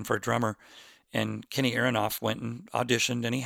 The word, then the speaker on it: Aronoff: Um, and you know, I was listening recently to Kenny Arnoff's, I say Arnoff, I think he pronounces it Aronoff, Kenny Aronoff,